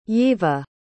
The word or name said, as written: yeva